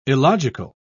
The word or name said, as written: illogical